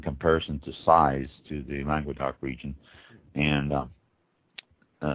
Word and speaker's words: Languedoc: And this is Languedoc, I think it's Languedoc, L-A-N-G-U-E-D-O-C, Languedoc. I believe that's how it's pronounced.